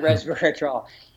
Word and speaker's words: resveratrol: Resveratrol? – That's the one, resveratrol.